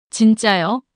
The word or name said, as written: 진짜요?